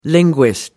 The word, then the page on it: linguist: American English